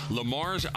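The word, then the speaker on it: Le Mars: Listens to us in Lamar's, Iowa. And if you don't, I think it's Lamar's, right? Yeah, Lamar's. It was Lamar. It's a silent S.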